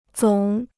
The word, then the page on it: 总: zǒng